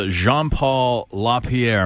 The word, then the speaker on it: Jean-Paul LaPierre: Well, our winner of the Nuts of Steel Award is actually on the phone right now, live, and his name is Jean-Paul LaPierre.